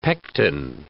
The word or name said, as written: pectin